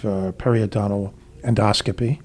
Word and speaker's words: periodontal endoscopy: So let's start today we're talking about the perioscope, actually, and we're talking about regenerative periodontal endoscopy.